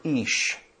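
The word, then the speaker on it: ish (אִישׁ): The Hebrew word for man here is the word ish. You could spell it in English I-Y-S-H. As some Bible dictionaries spell it. The Hebrew word for woman here is isha. You hear it. Man, woman, ish, isha.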